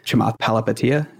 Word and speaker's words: Chamath Palihapitiya: And we had another Facebook executive recently come out, Chamath Palihapitiya.